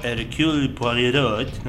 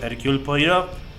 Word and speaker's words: Hercule Poirot: Tendremos el estreno el día jueves de la película denominada Cacería en Venecia, que pertenece a las historias del inspector Hercule Poirot. No sé si lo dije bien. ¿Está bien mi francés? – Va queriendo, sí.